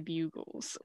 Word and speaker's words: Bugles: I just said buggles because I saw the word bug first.